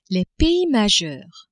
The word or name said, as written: pays majeurs